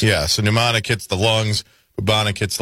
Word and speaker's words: pneumonic: Pneumonic. – Pneumonic. – As in pneumonia. – Yeah. Okay. All right. – That's okay, buddy. – Silent P.